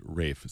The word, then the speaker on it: Ralph: A small note, his name looks like Ralph, but it's pronounced Rafe.